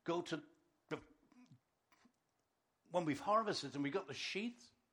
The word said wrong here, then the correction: sheaves: How do you pronounce that word? Farmers? Sheaves. Thank you. Sheaves. Sheaves. Let her pick from there and drop extra on the floor.